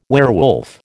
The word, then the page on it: werewolf: American English